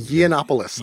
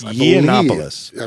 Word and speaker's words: Yiannopoulos: Last thing on my agenda for this show was this issue where this guy, Milo, I don't even know how to pronounce his name. – Yiannopoulos. – Yiannopoulos. – I believe it is Yiannopoulos.